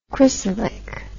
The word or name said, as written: cresylic